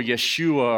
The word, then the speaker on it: Yeshua: In Hebrew, his name was probably pronounced more Yeshua or Yahuwah.